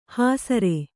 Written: ಹೆಸ್ಸರೆ

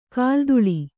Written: ಕಾಲ್ದೂಳಿ

